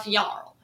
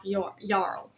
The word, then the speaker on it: Yarl: Ralph Yari. Or Ralph Yarl? Yeah, Ralph Yarl. I'm saying his last name wrong. Ralph Yarl.